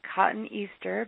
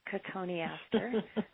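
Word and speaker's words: Cotoneaster: Looks like cotton Easter, but it is pronounced Cotone aster.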